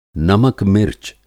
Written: नमक-मिर्च

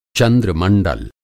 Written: पश्चिम एशिया

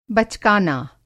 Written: बचकाना

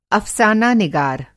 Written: अफ़साना-निगार

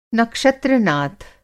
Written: नक्षत्र-नाथ

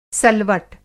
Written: सलवट

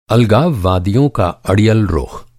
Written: अड़ियल

